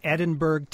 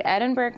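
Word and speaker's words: Edinburg: And as far as the small cities were concerned, you have, now I assume this town is pronounced Edinburgh, Texas? – That's right. Edinburgh, Texas at number five.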